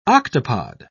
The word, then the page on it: octopod: British English